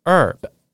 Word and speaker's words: herb: 那这边有个发音小提醒哦，香草或者是香料植物的英文啊，h e r b，但是 h 不发音，所以要念成 herb，herb 好像只有 e r b 这样子 herb。